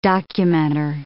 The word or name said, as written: documenter